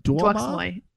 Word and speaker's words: DeuxMoi: Like, do you even do you even like follow Duoma? Like, that's where all the rich. Did I say it right? DeuxMoi. Duoma. – DeuxMoi.